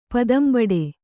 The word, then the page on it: ಪದಂಬಡೆ: padambaḍe